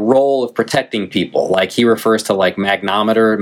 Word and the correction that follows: magnetometer: magnometer whatever you call it, like uh, um, magnetometer. – Yeah, yeah, magnetometer. Yeah, I missed a syllable in there,